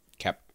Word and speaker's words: Koepp: It's unclear if this what's his name, the Coop, how do you say that? David Coop, K-O-E-P-P. David Coop, Coep. – I'm gonna say Kep. – Kep.